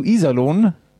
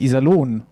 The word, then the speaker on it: Iserlohn: Sagst du Iserlohn und mit der Betonung auf I, dann rasten die schon aus im Internet. – weil es heißt nämlich Iserlohn und nicht Iserlohn.